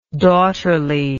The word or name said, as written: daughterly